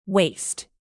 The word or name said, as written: waist